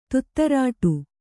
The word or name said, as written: ತುತ್ತರಾಟು